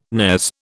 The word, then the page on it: nares: British English